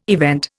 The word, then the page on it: event: Indian